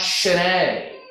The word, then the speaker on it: Sine: And the name of that pub, it looks like Sine, S-I-N-E, if you were reading it in English. But actually, it's Irish, and it's pronounced Shine.